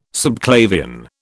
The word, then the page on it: subclavian: British English